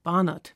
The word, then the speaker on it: Barnard: Christian Barnard oder Barnard, wie er englisch ausgesprochen wird.